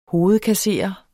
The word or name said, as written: hovedkasserer